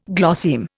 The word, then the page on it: glosseme: Indian